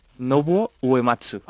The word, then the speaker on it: Nobuo Uematsu: Pero lo has nombrado fenomenal, ¿eh? – Sí. – Sí, sí. – Pronúncialo otra vez, por favor. – Nobuo Uematsu.